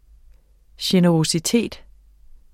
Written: generøsitet

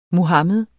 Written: Muhamed

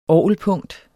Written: orgelpunkt

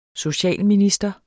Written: socialminister

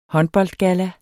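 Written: håndboldgalla

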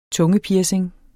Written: tungepiercing

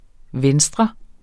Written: venstre

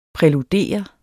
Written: præludere

